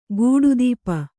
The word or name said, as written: ಕಡುತಪ